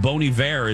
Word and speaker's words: Bon Iver: But I think we heard from a couple of my talkers on social media that Bon Iver is actually the correct pronunciation thing.